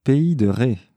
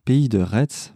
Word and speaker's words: Pays de Retz: Je disais en introduction avec un, un langage un peu moyen, pays e e de e On peut aussi prononcer pays de Ré, parce que j'avais eu une discussion off avec Geoffroy il y a un mois ou deux. On peut dire pays de Ré, pays de Retz, parce qu'on vient, on dit bien Metz, on dit pas Metz.